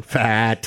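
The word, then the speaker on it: Pfaadt: Fat. – He's like his name. – The P is silent.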